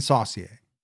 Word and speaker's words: Saucier: Alice Taylor was found in Gulfport, and Tracy Johnson was found in Saucier, which I believe is how you pronounce that. – I think you're correct. – He murdered Julia Critchfield in 1978 in Saucier.